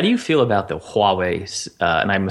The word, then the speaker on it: Huawei: Because it's like a Hua, right? – Yeah, yep. – The 6P, because I'm, you know, I probably wouldn't be one to buy a Huawei phone, but I might, actually.